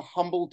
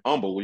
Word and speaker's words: Humble: Well, you know, the funny thing about being from Humble, you know, the H is silent, is I think for a lot of people, it was outside of Houston.